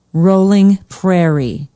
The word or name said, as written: rolling prairie